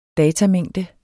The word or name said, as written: datamængde